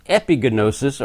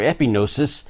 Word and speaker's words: epignosis: But Paul also prays that in addition to this knowledge, which is epignosis or epignosis, which means a more real knowledge, a more intense knowledge than just gnosis or gnosis, depending on whether you want to pronounce the G or not.